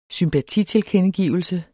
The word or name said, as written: sympatitilkendegivelse